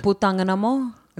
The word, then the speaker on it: Putang ina mo: Putang ina mo.